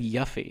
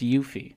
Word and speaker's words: Yuffie: I've always said Yuffie because there's two Fs, but I guess it's pronounced Yuffie.